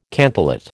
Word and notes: cantillate: American English

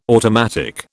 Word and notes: automatic: British English

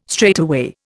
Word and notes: straightaway: Indian

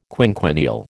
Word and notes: quinquennial: American English

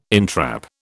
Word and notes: entrap: British English

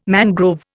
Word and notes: mangrove: Indian

